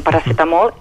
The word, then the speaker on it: paracetamol: Per tant, a veure, eh, recordem, eh, sobretot les pronúncies, per exemple, el paracetamol, no, no parlem d'un paracetamol, eh, vigilem, és un paracetamol i per tant aquesta C l'hem de pronunciar en català eh?